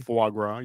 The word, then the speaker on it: foie gras: One of the best things I've ever eaten was a faux gras. How do you say that properly? – Foie gras. You're frozen.